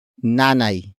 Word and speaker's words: nanay: It is the focus marker that introduces the main topic of the sentence, and that is nanay. Nanay. Nanay. Nanay is spelled as N-A-N-A-Y.